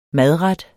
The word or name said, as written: madret